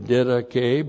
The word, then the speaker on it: didache: N plus the dative of didache by means of the doctrine, through the doctrine. Didache, great word. D-I-D-A-C-H long E.